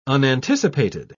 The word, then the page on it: unanticipated: British English